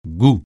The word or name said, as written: goût